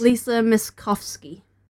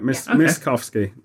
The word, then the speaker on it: Miskovsky: So just to clarify, it's Still Alive by Lisa Lisa mispronounced the surname, yes. – Lisa Miskovsky. – Oh, right. Miskovsky. Theme of Mirror's Edge.